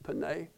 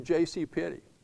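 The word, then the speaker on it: Penney: Well, maybe I'm not pronouncing it. That's the way fancy folks pronounce it, Panay. I think us common people say JCPenney.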